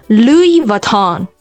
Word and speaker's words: Louis Vuitton: Versace 范思哲，那还有一个是 LV， LV Louis Vuitton。 – Louis Vuitton 这也是一个法国品牌哈，而且听那些 native speaker 或者说西方人再去说 LV 这个品牌的时候，他们从来不会说 LV，他们就是全称说就是 Louis Vuitton。